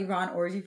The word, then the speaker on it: Yvonne Orji: But I want to talk about Yvonne Orji for a second.